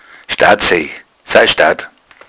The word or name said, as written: staad